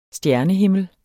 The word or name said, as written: stjernehimmel